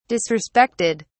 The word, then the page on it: disrespected: British English